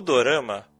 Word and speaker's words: dorama: Ah, o dorama, a gente fala dos J-dramas. Porque é um drama, na verdade, mas é como o japonês pronuncia drama. Hum.